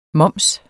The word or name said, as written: moms